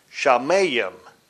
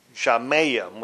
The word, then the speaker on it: shamayim: It's spelled S-H-A-M-E-H, and it's pronounced shamay, which is the plural form of the word. Now, the singular version is shamayim. Shamayim, which is spelled S-H-A-M-A-Y-I-M, Shamayim.